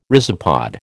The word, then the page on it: rhizopod: American English